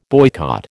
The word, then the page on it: boycott: American English